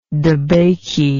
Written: debakey

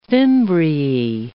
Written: fimbriae

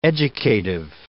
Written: educative

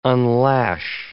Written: unlash

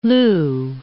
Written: loo

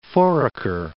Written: Foraker, Mount

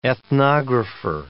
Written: ethnographer

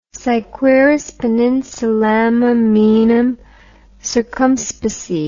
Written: si quaeris peninsulam amoenam circumspice

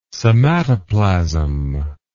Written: somatoplasm

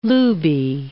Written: looby

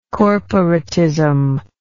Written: corporatism

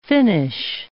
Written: finish

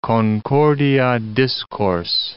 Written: concordia discors

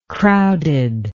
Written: crowded

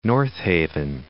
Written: North Haven

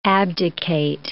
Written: abdicate

